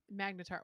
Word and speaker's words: magnetar: I really like, excuse me, the word magtar. So that's Magnetar. – Wow, and I butcher it.